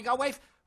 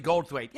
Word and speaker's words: Goldthwait: Bobcat Goldthwait or Bobby Goldwaif. As all the the the Boston guys pronounce it. Hey, you're friends with that Bobby Goldwaif, aren't you? Bobby Goldwaif. Well, it's actually Goldthwait.